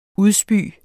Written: udspy

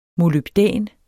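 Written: molybdæn

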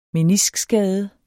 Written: meniskskade

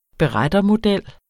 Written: berettermodel

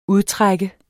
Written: UDTRAK

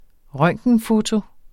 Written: røntgenfoto

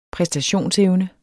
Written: præstationsevne

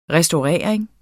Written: restaurering

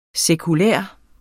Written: sekulær

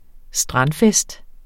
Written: strandfest

